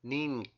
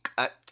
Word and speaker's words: Ninkat: And finally, the letter V, which represents the short U sound, and it appears in our word for forehead. And the word is Ninkat. Ninkat.